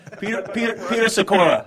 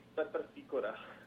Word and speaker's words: Petr Sikora: Peter Sikora. How about Peter Sikora? Is that just like it sounds, or no? – Peter Sikora would be Petr Sikora.